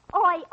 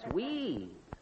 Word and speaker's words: oui: Oi, oi. – Oi, oi? What is that? – Oh, oi means yes in French. It's spelled O-U-I. – Oh, that's wee.